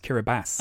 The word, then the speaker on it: Kiribati: It's actually pronounced Kiribati.